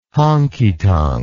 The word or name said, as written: honkytonk